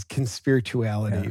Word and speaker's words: Conspirituality: And we all have, thinking about like, yeah, the conspiratorial podcast, did I say that right? – Conspirituality. – It's Conspirituality. Yeah.